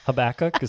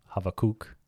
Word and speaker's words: Habakkuk: Habakkuk is fine. I think in English, if you want to say it in Hebrew, Habakkuk.